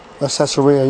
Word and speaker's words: Caesarea: He called him to move go down to that desert route, and then send them off to uh, Caesarea. I, I, always pronounce that wrong a lot, so uh, Caesarea.